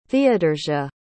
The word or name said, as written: theodorsia